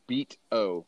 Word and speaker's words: Beato: It's spelled Beato, Rick Beato. It's like beat o, but it's pronounced Beato.